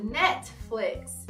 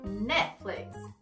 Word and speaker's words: Netflix: É assim que a gente fala em português aqui no Brasil, mas a forma correta de pronunciá-lo é Netflix. Essa é uma forma um pouco mais britânica, porque eu fiz aquele som forte do T. Eu mesma pronuncio mais com o inglês norte-americano, Netflix.